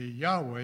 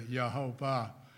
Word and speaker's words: Yahweh: Then scholars began to think, well, really the correct pronunciation of Yahweh is of the Tetragrammaton, I should say. The Tetragrammaton, the YHWH, should be Yahweh instead of Yehovah.